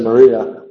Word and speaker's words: Maria: Normally when we want to say Maria, we mispronounce it as Mariah in English,